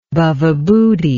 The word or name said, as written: Bhavabhuti